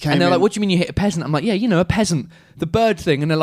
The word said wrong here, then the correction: pheasant: like, oh no, a pheasant. Okay. PH, you got to add the H. You got to pronounce the H.